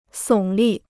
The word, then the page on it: 耸立: sǒng lì